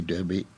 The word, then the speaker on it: Derby: The great American horse race is, you must know, the Kentucky Derby, pronounced Derby for interesting reasons we'll go into some other time.